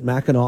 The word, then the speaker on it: Mackinac: I've seen it spelled with an AC at the end. So I don't know. I guess that's how they pronounce it. I've gone through several pronunciations of this thing. They think most people from Michigan pronounce it Mackinac Island.